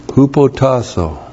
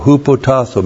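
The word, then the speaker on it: Hupotasso (ὑποτάσσω): Hupotasso. H-U-P-O-T-A-S-S long O. Hupotasso, military term in the ancient world, meant to be subordinate to authority.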